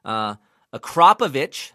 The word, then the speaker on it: Akrapovic: Akrapovic, that's how you say it.